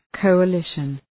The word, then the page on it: coalition: ,kəʋə'lıʃən